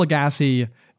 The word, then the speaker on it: Lagasse: Lagasse. So Emeril Lagasse is the original.